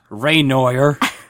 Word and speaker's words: Renoir: Renoir.